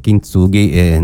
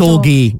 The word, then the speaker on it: Kintsugi: Lei dice Kintsugi normalmente. Buongiorno. – Ma la perdoniamo. Buongiorno. Matilde Brizzi. Buongiorno. Sì, perché in giapponese dovresti mettere l'accento Kintsugi. – Kintsugi. Sì. In ogni sillaba, sì, capito?